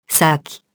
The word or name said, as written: sac